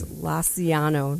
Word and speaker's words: Lanciano: I think it's Lanciano, not whatever.